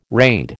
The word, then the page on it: raid: American English